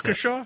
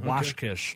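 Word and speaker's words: Washkish: I'm just going to try and pronounce Waukesha? – It. – Waukesha? – No, it's W-A-S-H-K-I-S-H. So Washkeesh.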